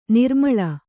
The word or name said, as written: ನಿರುಮ್ಮಳ